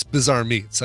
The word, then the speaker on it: Bazaar Meats: And it is, you know, it's spelled Bazaar Meats, B-A-Z-A-A-R, like, you know, an old trading market. But it's Bizarre Meats.